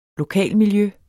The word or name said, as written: lokalmiljø